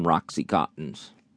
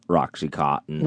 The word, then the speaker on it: Oxycontin: I made an appointment to see my doctor to get some Oxycontins. – Oxycontin is his drug of choice? – Yeah. So, but he mispronounces it and calls it Roxycontins.